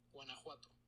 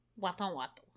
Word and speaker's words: Guanajuato: Guanajuato. Guanajuato. – Play it right into the microphone. Oh. – Guanajuato.